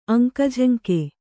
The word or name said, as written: ಅಂಕಝಂಕೆ